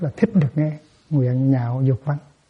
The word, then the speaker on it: nhạo: Chứ không có nói nguyện lạc dục văn. Nó là chữ lạc, nhưng mà đọc là chữ nhạo. Sau này sẽ có một chữ lạc khác, mà cũng phải sửa lại thành chữ nhạo.